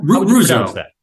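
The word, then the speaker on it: Ruzzeo: It's R-U-Z-Z-E-O. Is that Ruzzo? Ruzzo? – Ruzzo. – Ruzzo. Ruzzo. Okay, yeah. The easy way.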